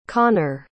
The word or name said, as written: connor